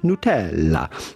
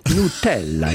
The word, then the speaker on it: Nutella: Of course, you can always say Nutella, but with the typical Italian pronunciation, how would you say it in Italy? Well, that would be Nutella. Nutella.